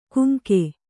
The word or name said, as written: ಕುಂಕೆ